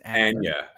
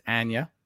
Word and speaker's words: Anya: So it's Anya Taylor-Joy, and that's the correct pronunciation of her name is Anya. It's not yeah. Anya. I've been saying Anya. – Yeah, it's Anya.